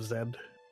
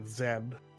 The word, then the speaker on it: Zed: There was a particularly fun moment where we were definitely just arguing about the pronunciation of Zed, because everybody else was insisting it was pronounced Z, and that's just wrong. Only two people got it right in raid. That was me, and it was Stone. – Yeah, that sounds about right. – I don't know why it's such a big... I don't know why people were so stubborn. I mean, it's Zed.